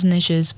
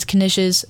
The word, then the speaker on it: Knishes: And I did look that up, how to pronounce that, because I thought it was Knishes, but it's not. It's Knishes, a type of food.